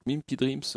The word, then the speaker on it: Mimpi Dreams: Donc Mimpi Dreams, euh, comme ça se prononce, hein. M-I-M-P-I et plus loin Dreams comme les rêves.